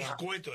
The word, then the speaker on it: escueto: Pone ecueto. Ecueto. – Escueto debe ser. – Ecueto, dijo. – ¿Escueto? – Sí. – ¿Y cómo se dice? Escueto. – Escueto. – ¿Escueto? – No, escueto. Acá...